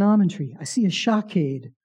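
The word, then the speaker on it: shokade (שׁוֹקֵד): And the word for I am watching is shokade. It rhymes.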